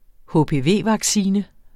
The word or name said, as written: HPV